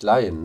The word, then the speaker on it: Lyon: Also ihr lieben Zuhörerinnen zu Hause, im Waldhaus Verlag, Sampada Yoga von Ursula Lyon, ausgesprochen nämlich Lyon, ne? Ist das richtig? – Ja, für Lyon.